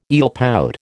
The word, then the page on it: eelpout: American English